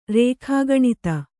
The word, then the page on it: ರೇಖಾಗಣಿತ: rēkhāgaṇita